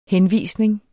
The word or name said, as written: henvisning